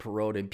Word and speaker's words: Perrodin: Um, so check out Dr. David Perrodin, P E R R O D I N.